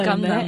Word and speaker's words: Gangnam: Gangnam.